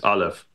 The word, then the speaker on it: Aleph: I'm pretty sure I'm pronouncing it right. It's Aleph.